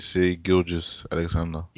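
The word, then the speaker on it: Shai Gilgeous-Alexander: How do you pronounce his name? Shea Alexander. – Shea Gilgeous Alexander. – Yeah, that's it. Gilgeous Alexander.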